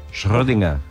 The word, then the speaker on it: Schrödinger: ¿Cómo se pronuncia Schrödinger? Correctamente. – Schrödinger.